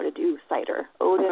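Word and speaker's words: seiðr: it's pronounced seether, cider, depending on what country you're coming mm-hmm. – from. Cider is Norwegian. And that's my ancestry, so I choose to call it cider.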